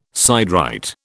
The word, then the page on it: siderite: British English